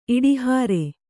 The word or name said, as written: ಇಡಿಹಾರೆ